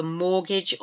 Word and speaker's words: mortgage: Mortgage, but it's got a silent T again in the middle of the word. M-O-R-T-G-A-G-E. Mortgage.